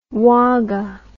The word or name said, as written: Wagga